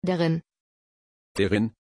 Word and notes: deryn: German

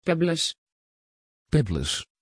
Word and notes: pebbles: Dutch

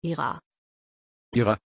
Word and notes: ira: German

